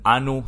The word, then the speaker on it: Anu: They had like three major gods, Enlil, Enki, and Anu, sometimes pronounced An.